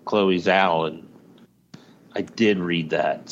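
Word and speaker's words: Chloé Zhao: Reason is director Chloe Zhao.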